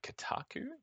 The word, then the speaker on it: Kotaku: So staying on the theme of people being offended, there was this article on Kotaku.